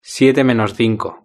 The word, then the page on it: siete menos cinco: European Spanish